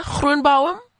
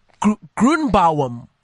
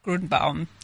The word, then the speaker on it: Gruenbaum: Belinda Gruenbaum? Did I say it right? No. Please correct me. Gruenbaum. – Gruenbaum. Yes.